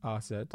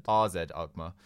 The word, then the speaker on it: AZ Alkmaar: He's the AZ Alkmaar keeper. AZ Alkmaar? How do you pronounce it? RZ. – RZ Alkmaar.